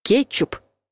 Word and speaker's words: кетчуп: Согласный «к» в слове «кетчуп» произносится мягко, поэтому вариант «кетчуп» ошибочный. Ну и расскажите уже вашей бабушке, что «кетчупов» не существует. Ударение в этом слове падает на первый слог.